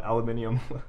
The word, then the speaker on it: aluminium: aluminium. – I just don't get where the extra syllable comes from.